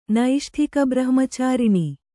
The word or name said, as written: ನೈಷ್ಠಿಕಬ್ರಹ್ಮಚಾರಿಣಿ